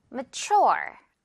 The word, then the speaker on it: mature: 그리고 발음, mature 이렇게 발음이 됐었습니다. 깃털 앞부분에 있고요. 뒷부분에 강세. mature.